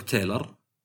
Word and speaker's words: Taylor: تايلر وكلها تكتب تايلر.